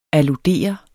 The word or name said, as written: ALLUDERER